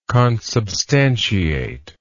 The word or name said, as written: consubstantiate